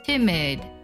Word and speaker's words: timid: 它读的时候呢，两个 i 分别读作短音 a， timid。